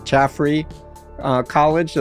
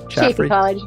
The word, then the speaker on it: Chaffey College: You were a 2022 Faculty Lecturer of the Year at Chaffrey College. Did I say that right? – Chaffrey College, but it's okay.